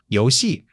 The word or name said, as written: you2 xi4